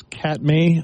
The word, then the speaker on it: Katmai: This is Katmai, I think is the name, how you say it? K-A-T-M-A-I, Katmai National Park and Wildlife Preserve.